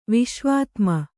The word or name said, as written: ವಿಶ್ವಾತ್ಮ